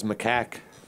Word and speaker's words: macaque: They're Macaues. Macaues, that's the name of the... Is that how you pronounce that? – I thought it was macaque. – Macaque. You know, you're right.